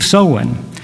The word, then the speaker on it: Samhain: It's spelled S-A-M-H-A-I-N. You wouldn't think that it would be Samhain when it's spelled that way, but it is.